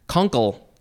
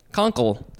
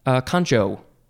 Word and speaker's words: concha: Next is the second form of this same word, but instead of pronouncing it concha, it is concha. Concha, like the way it's spelled. Could also be concho.